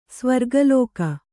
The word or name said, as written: ಸ್ವರ್ಗಲೋಕ